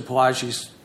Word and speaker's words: Palagi: Is that how you pronounce Palagi.